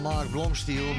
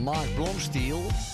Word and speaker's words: Blomstiel: Op zijn Amerikaans moet je dan zeggen Mark Blomstiel, maar je moet het eigenlijk Nederlands uitspreken, want hij komt uit Rotterdam. Mark Blomstiel.